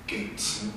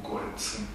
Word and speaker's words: Coetzee: de un mismo autor sudafricano que se llama John Maxwell Coetzee, o Coetzee, como quieran. Gente que lo conoce lo llama Coetzee, los que no lo llaman Coetzee, que es como se escribe.